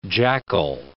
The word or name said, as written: jackal